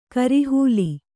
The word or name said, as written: ಕೋರೆಹಲ್ಲು